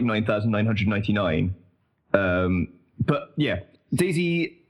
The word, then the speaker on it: DayZ: The letter is Z. It is pronounced Z, so I'm calling it DayZ, because the alternative is to call it Daisy, and I'm not going to do that.